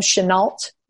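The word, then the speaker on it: Shenault: I know a lot of teams love Chenault. Am I pronouncing that? – He did. Yes, correct.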